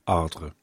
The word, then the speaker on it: Ardres: I could never do that R-E sound at the end of a word in French. Anyway, there you go, Ardres.